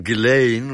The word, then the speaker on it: Ghislaine: Yes, Basil, his ghastly sister Arabella, his equally ghastly wife Araminta, and their yucky children, Toby with a silent H, and Ghislaine, with any number of gratuitous letters that aren't pronounced.